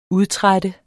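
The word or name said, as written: UDTRÆT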